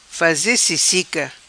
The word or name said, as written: Faze Sissica